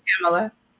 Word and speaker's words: Tamala: Yeah, you know, I felt to, can you say your name again, please? Because I don't want to. – Who, me? – Yeah, you. – Tamala. – Tamala. Oh, I always pronounce it wrong.